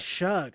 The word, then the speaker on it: Shough: Is it Shough or Shough? – It's Shug, because I was saying, okay, Shaw too, like, and everyone's like, it's Shug.